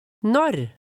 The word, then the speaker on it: Når: Når? Når? – Pronounce the R sound at the end carefully. It's quite different from the American R.